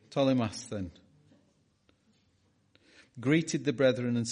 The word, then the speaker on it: Ptolemais: And when we had finished our voyage from Tyre, we came to, and this is another one of those, PT, how do you say that? PTOL. Ptolemais.